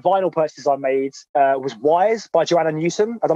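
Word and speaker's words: Ys: It's like Y. – Wise. – Like, that's how it's spelled. Just Y-S.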